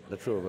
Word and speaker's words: La Trobe: Do I pronounce it right? La Trobe. La Trobe, okay.